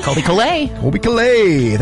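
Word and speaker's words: Colbie Caillat: Yeah, that one right there, that singer-songwriter Colby Colby Calais.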